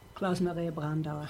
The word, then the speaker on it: Klaus Maria Brandauer: How's it pronounced again? – Klaus Maria Brandauer. – Oh, that's better.